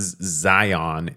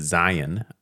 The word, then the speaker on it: Zion: And it's not Zion, it's Zion.